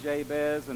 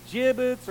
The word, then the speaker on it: Jabez: And being from the South, Tim and Rachel will know this, like Eagleville, Tennessee, we told the church, here's our son Jabez, and they would pronounce it Jabez or gibbets or Jarvis, or it's like just a lot of heartache in the name itself.